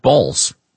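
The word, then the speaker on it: Baals: Do we know if they actually did decide to name their, because it's a B-A-A-L-S is the actual spelling, but the pronunciation is in fact Balls.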